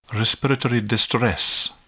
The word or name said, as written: respiratory distress